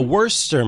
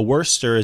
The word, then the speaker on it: Worcester: A Worcester man. For folks who don't know, Worcester is in Massachusetts. It's spelled like it's Worcester, but it is not Worcester. It's Worcester.